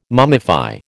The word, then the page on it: mummify: American English